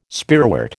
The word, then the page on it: spearwort: American English